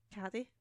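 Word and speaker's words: Cady: Why, what do they pronounce it as? – Caddy. – Caddy. Well, it is.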